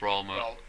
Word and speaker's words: Raoul Moat: It's Raoul Moat.